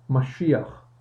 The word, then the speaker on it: Mashiach: Messiah is the English pronunciation for the Hebrew word Mashiach, which means anointed or being chosen for a task.